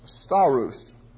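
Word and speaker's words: stauros: It's pronounced staros.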